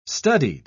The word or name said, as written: studied